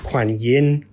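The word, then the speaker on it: Guanyin: And there are different pronunciations of her name. So she's often called Quan Yin as well. Quan Am is very much the Vietnamese spelling.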